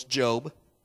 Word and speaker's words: Job: If you were to flip through your Bible, you would see that it looks like there's a book in the Bible called Job, but it's actually a long O. It's Job.